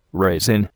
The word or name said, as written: raisin